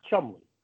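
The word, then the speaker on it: Cholmondeley: There is a word, I think it's spelled Chomondeley. And it's pronounced Chumley.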